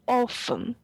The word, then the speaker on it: often: They pronounce T in often, じゃん。Often でしょ？そうそうそうそう。